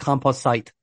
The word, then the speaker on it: composite: It's pronounced composite. – Thank you. – Composite. Composite.